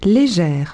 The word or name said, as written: legeres